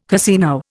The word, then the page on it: casino: Indian